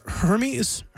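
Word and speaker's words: Hermès: This because, and I don't know if I'm pronouncing it right, but is it Hermes? – Yeah, luxury brand.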